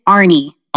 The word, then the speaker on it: Arne: It's going great, and it is Arne.